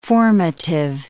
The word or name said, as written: formative